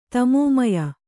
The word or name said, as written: ತಮೋಮಯ